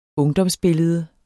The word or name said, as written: ungdomsbillede